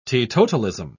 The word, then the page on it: teetotalism: British English